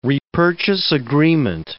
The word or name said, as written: repurchase agreement